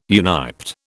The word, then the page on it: uniped: British English